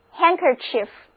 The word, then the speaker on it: handkerchief: 再来看这个单词 handkerchief, H-A-N-D-K-E-R-C-H-I-E-F, handkerchief. 注意因为辅音 D 跟 K 连在一起，前面的辅音就失去爆破，不发音，所以整个词就读作 handkerchief。